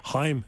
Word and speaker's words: Haim: One of the Haimses. – Haim is how you pronounce it.